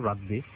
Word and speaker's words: রাদবি: রেজা ভাই অনেকে আসলে আমার নামের উচ্চারণটা কঠিন একটু, রাদবি অনেকে রাব্বি বলে,